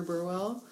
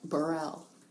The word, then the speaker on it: Burwell: is a really good score by Carter Burwell. Um, there's a way to pronounce that that I didn't know. I think it's like Burrell.